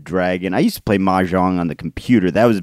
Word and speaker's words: mahjong: Uh, the uh, theme on Monday was uh, mahjong tile. Is that how you say it? Mahjong. Mahjong. – Mahjong yeah, yeah.